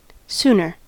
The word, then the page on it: sooner: American English